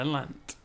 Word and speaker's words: Landt: The Lant Trio, LANDT. Do you reckon the T silent or the D silent or Lant? – Yeah, Lant.